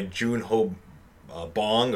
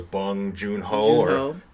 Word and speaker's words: Bong Joon-ho: It is directed by Jun Ho Bong, or as in Korea, I guess you go as Bong Joon-ho. That's even the correct way to say it. Of course, that's just the American way of speaking this director's name.